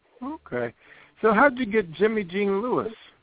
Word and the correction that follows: Jimmy Jean Louis: Jimmy Jean Louis, that's the French pronunciation. He's Haitian.